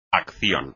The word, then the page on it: acción: espagnol